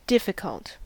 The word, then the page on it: difficult: American English